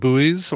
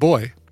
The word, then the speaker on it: buoy: And I said, by the way, how do you pronounce B-U-O-Y? And he's like, I pronounce it like boy. I'm like, okay. He says, but I know a lot of my American friends pronounce it buoy.